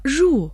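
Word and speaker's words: жу (入): Слово «жу» произносится в 4-м тоне.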